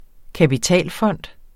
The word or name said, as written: kapitalfond